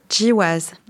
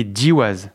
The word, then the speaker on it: GWAS: Le premier, c'est ce qu'on appelle les Jiwas. – Les GWAS. – Alors, ça s'écrit G-W-A-S.